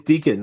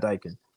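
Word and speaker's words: Dieken: It's Deacon, not Dyken.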